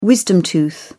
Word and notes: wisdom tooth: British English

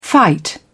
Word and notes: fight back: British English

